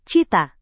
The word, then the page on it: cita: Indonesian